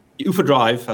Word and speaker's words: UFO Drive: As you mentioned in the introduction, UFO Drive, as we pronounce it here in Europe, came about from my own personal frustration, having gone through hundreds of poor car rental experiences.